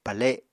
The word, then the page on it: pale: Apatani